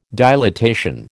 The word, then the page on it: dilatation: American English